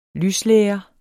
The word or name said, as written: lyslære